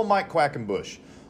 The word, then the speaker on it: Mike Quackenbush: You can follow us at How to Become a Pro Wrestler, but also follow Mike Quackenbush. Look up Mike Quackenbush. The name is spelled exactly like I said it there.